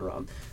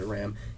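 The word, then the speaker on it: Rahm: You got, um, I mean, you just got John Ram, John Ram, or uh, his name is Ram. That's not John Rom, Um, it's spelled Rom. I think he's supposed to pronounce it Ram.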